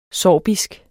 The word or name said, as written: sorbisk